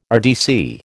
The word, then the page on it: RDC: American English